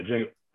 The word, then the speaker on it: Django: Yeah, Django. I know. Right. The two of them at the bar. The D is silent.